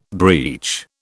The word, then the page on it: breach: British English